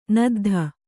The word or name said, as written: ನದ್ಧ